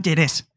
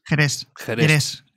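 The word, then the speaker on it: Gerês: Se llama Jerez. – ¿Cómo se escribe? – G-E-R-E-S. – Como Jerez. – Jerez.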